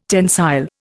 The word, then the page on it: tensile: Indian